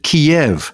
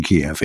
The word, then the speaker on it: Kiev: And he said, I would suggest Kiev, like chicken Kiev, in the traditional way.